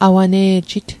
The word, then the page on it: awane'jit: Mi'gmaq